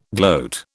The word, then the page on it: gloat: British English